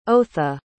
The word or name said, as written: otha